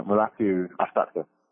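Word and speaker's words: Mulatu Astatke: And, you know, I have a hard time remembering how to pronounce his name, but Mutla Astake? – Oh, Mulatu. – Mulatu. – Yeah, Mulatu Astake.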